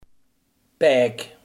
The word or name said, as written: pek